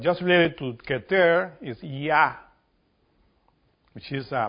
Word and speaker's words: Yah: Yah. This is how you pronounce it in Hebrew.